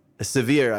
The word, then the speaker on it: Sevier: Sevier, I should say, is the proper pronunciation, Sevier County High School Auditorium.